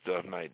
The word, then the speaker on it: Dove Knight: You can, you know, I have a Facebook, Dove Night, Facebook, you know, D-O-V-E. It's spelled K-N-I-G-H-T on Facebook, but it's really My name is Dove Knight, DoveNight.